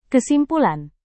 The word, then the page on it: kesimpulan: indonesian